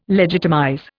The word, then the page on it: legitimize: Indian